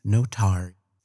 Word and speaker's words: Notary: I am a Crash Pod Productions station management, Tim Notary. It rhymes with Atari.